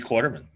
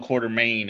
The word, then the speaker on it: Quarterman: Shibley Quarterman. – Woo! Hello. Thank you for having me. – Did I pronounce the last name right? – Quarterman. Yeah, perfect. – It's not like Alan Quartermain. It's Quarterman.